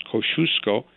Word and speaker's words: Kosciuszko: Because we are one state, and because that bridge, Kosciuszko, as the Polish ambassador told me, it's pronounced Kosciuszko.